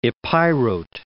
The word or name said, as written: Epirote